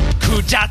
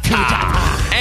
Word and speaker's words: Kujata: So remember, they are pronounced Kujata, Kujata. and Bahamut. Bahamut.